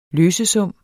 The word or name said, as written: løsesum